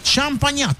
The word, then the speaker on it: Champagnat: Es San Marcelino Champagnon. Champagnon. ¿Champañá? ¿Champañán? ¿Cómo es la cuestión? Champagnon. Champagnat. Ahí está.